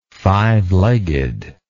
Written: five-legged

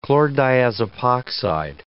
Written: chlordiazepoxide

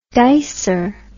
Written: dicer

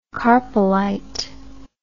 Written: carpolite

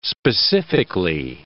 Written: specifically

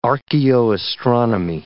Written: archaeoastronomy

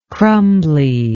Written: crumbliness

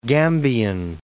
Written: Gambian

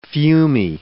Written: fumy

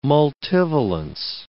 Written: multivalence

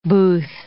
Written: booth

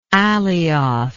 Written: alioth

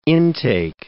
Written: intake

